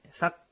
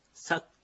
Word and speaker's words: サッ: サッというものなんですが、このカタカナで書きますとサの後にちっちゃいクがついています。これがちょっと、まあ、アイヌ語にない、アイヌ語にはの独特の発音でして、日本語にはない発音です。ちっちゃいクなんですね。もう息を止めるような発音です。